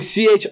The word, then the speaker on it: Chisholm: Earlier in the month, the CEO of Hi-Rez, Stuart, how do you pronounce it? Chisholm? – Stuart Copeland? – No, it's like Chisam.